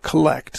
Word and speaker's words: collect: So I think in English we put the accent on the first syllable because to differentiate it from the verb collect. So it's collect.